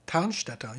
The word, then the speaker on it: Tannstetter: How do you spell it? – T-A-N-N-S-T-E-T-T-E-R. – Tanstetter, yeah.